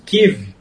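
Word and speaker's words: Kyiv: A Rússia, dessa forma, tenta preservar sua influência sobre uma área que já foi a cabeça da União Soviética, lembrando que Kiev, o Kiev, que é a pronúncia ucraniana,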